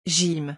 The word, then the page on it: gym: French